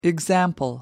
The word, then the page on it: example: American English